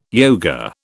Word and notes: yoga: British English

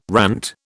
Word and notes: rant: British English

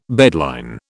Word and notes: bedline: British English

